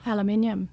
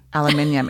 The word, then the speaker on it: aluminium: And how do you say aluminum when you're from Australia? – Aluminium. – Aluminium. It sounds way better.